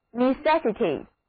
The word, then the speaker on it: necessity: 注意第一个字母 E 的读音是短的 E，而第二个字母 E 在这里则变成了 E，正好和 necessary 里的读音相反，中音也跑到了第二个音节 necessity。